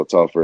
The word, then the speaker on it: Telfer: So everything that all my social media handles, it's just, you know, at Randall Telfer is my Instagram, Twitter.